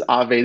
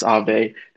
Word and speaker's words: Aave: But at the time he was still doing speaking events and he would come on and he was like, this is Aave. This is how you pronounce Aave.